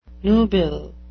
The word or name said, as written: nubile